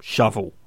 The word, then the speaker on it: Shovell: So I asked guitarist Johnny Gorilla, who was able to confirm that they pronounce it Shovel, as in the spade-like tool loved by road menders and serial killers alike,